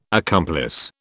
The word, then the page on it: accomplice: British English